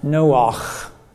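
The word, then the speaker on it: Noach (נח): The word Noah, and in Hebrew it's pronounced Noach, Noah.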